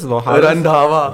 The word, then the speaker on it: Randhawa: What is his? – Randhawa. – Randhawa. I've just butchered his name.